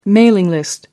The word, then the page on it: mailing list: American English